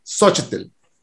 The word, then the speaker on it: Xochitl: And that's fine, but like, okay, here's how you pronounce it. Xochitl.